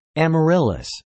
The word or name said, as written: amaryllis